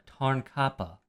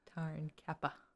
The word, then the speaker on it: Tarnkappe: Tarnkappe. – Tarnkappe. – Ka. It's K-A-H. Ka. Pa. – Tarnkappe. That's the phonetic spelling. – I'm sorry. – The actual spelling is K-A-P-P-E.